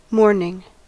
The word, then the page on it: mourning: American English